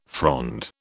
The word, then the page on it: frond: British English